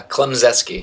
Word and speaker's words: Klemczewski: I want to make sure I'm saying this right. Klemzewski, is that right? Uh, Klemzeski. – Klemzeski. Okay, close enough.